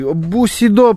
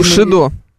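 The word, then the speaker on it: Bushido: Вообще правильно, по-моему, Бусидо. – Да, потому что в Японии нет звука Ш.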